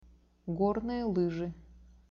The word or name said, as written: gornye lyzhi